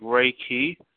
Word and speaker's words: Reiki: It's actually pronounced like Reiki, and it's actually two words that's put together as one.